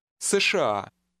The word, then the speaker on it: США: США. США. – In Russian, a set of initials is sometimes pronounced as if it were a word of its own.